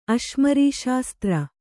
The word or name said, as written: ಅಶ್ಮರೀಶಾಸ್ತ್ರ